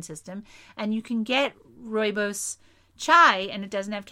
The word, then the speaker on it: rooibos: It's rooibos is Afrikaans for red bush. It sounds sort of Dutch, right? So it's properly pronounced rooibos. Rooibos.